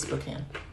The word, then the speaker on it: Spokane: So just so you know, all three of our listeners, it's Spokane.